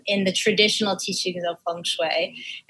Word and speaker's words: feng shui: And am I even saying it correctly? Feng shui? – Feng shui. That's how I was taught.